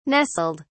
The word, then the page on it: nestled: British English